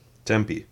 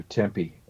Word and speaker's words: Tempe: Tempe. – Is it Tempe? – Pretty sure that's how Derrick pronounces it in the interview. Yeah. That's where they lived, yeah. – You're right, too. Tempe, Arizona.